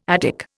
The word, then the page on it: Attic: Indian